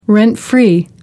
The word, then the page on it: rent-free: American English